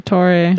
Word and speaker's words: Dottore: How do you say it again? Sorry. The the Dottore.